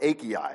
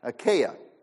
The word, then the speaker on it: Achaia: But there, the people of Macedonia and Achaia, I just learned how to pronounce that. I always grew up with Achaia. And you're not supposed to say Achaia is the right way to say that. Achaia. Spent some time on that one because I knew I wasn't pronouncing it right.